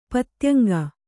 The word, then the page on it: ಪತ್ತ್ಯಂಗ: kannada